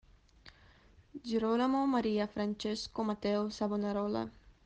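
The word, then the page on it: Girolamo Savonarola: italiana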